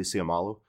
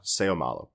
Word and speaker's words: Seumalo: Did I say that correctly? Seumalo. Like Junior Seau. Seumalo. Got it.